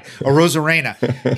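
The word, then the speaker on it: Arozarena: I'll say it right. Rosarena.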